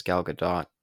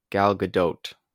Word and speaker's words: Gal Gadot: Yeah, and I feel like Gal Gadot, or actually I found out how to say her name. I think in the last episode I said it was Gal Gadot. It turns out it's a combination between the two.